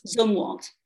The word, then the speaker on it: Zumwalt: Am I saying that right? Zumwalt? – Zumwalt. – Zumwalt. – Yes.